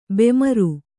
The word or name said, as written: ಬೆಮರ್